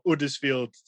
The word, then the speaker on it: Huddersfield: In Yorkshire, we tend not to pronounce our H's. I'm over in Huddersfield, or Uddersfield, as we probably would pronounce.